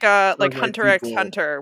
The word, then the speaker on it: Hunter x Hunter: Or like Hunter X Hunter, where they're like ant people. – Fun fact, the X is silent.